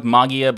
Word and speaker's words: Mageia: I'm going to go with Mageia because technically that's the Greek pronunciation, or so I have been told.